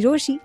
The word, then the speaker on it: Rochie: मेरा नाम है मिर्ची रोशी, नाम की स्पेलिंग है, आई जस्ट सेड स्पेलिंग है, ROCHIE.